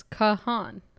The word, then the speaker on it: Kahan: Per Google, I said, how do you pronounce Noah Kahan? And it's Kahan.